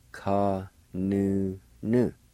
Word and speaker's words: kanuna: Ka nu na. Ka nu na. Ka nu na. As I mentioned a while ago, some may pronounce with the N-U. That would be pronounced like this. Ka nu nu.